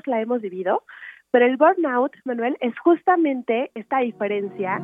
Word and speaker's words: burnout: Burnout.